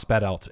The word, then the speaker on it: Badelt: Klaus Badelt. – Klaus whom? – Klaus Badelt. It's spelled Badelt. – B-A-D-E-L-T.